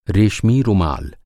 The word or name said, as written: रेशमी